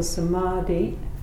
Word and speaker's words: samadhi: And the R, the long A in the middle, samadhi, means like uh, it has a movement.